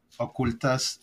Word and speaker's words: Ocultas: But there's a really cool podcast called Historias Ocultas, but Ocultas, it's spelled OCOOLTAS, like the word cool.